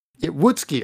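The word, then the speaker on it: Woutske: So this time, they went after Watsky. Wotske. I don't know if I said that right. It's W-O-U-T-S-K-E. That was the founder of Bark. Wotske. Okay. Wotske. There we go.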